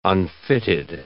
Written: unfitted

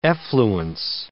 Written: effluence

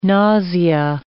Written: nausea

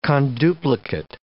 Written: conduplicate

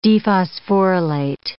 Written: dephosphorylate

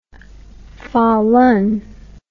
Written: falun

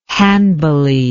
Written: Hanbali

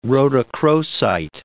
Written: rhodochrosite

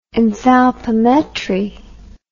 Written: enthalpimetry

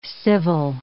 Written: civil

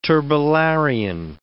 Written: turbellarian

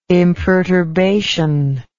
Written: imperturbation

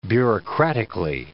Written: bureaucratically

